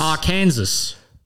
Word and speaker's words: Arkansas: I don't understand how that, because I always saw it and it was like spelled like Kansas. – Arkansas.